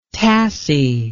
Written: tassie

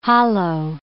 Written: hollow

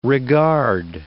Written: regard

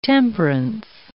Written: temperance

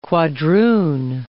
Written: quadroon

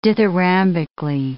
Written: dithyrambically

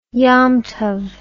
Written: yom tob

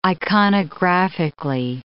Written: iconographically

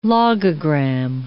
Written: logogram

